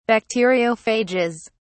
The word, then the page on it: bacteriophages: British English